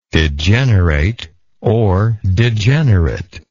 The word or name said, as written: degenerateness